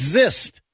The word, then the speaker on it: xyst: Or is it a Z? – Hey. – It's zist. That's it. It's zist. X-Y-S-T. Zist.